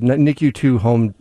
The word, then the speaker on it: NICU2 Home: And NICU2, that's how you say it. NICU2 Home is how you say it.